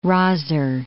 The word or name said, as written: rozzer